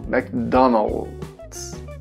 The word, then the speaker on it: McDonald's: A tendência é o L você falar com som de U aqui no Brasil. O L no inglês a gente fala O, oh, ou McDonald's.